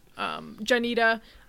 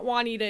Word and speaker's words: Juanita: Janita, not Juanita. It's spelled like Juanita, but it's Janita because I named her after one of the managers at my very first job, who pronounced her name as Janita.